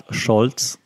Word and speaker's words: Scholz: So that's what Olaf Scholz, and that's how I pronounce it, Olaf Scholz, has to do has to work with now that he's our first male chancellor in 16 years.